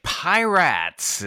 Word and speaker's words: Piratz: Pirates is how it is. P-I-R-A-T-Z. Pirates.